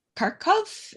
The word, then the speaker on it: Kharkov: You have an American based in, I think, in Kharkov. Is that the right pronunciation? Yes. Gonzalo.